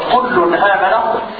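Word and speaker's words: كلٌّ آمن: فحينما تقول كل أو تقرأ كل فأنت تنطق بنون ساكنة إذا هذه النون ساكنة أتى بعدها همز قطع فلزم عند ورشم أن ينقل حركة الهمز إلى هذه النون ساكنة فصارت كل آمن كل آمن.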